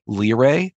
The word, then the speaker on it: Lire: And there's a Mac version called Lire. That's how it's pronounced. You're familiar with it? – Oh, yeah. That's fantastic. – It's awesome. Some speech synthesizers might call it Lire, but I believe it's supposed to be Lire, L-I-R-E.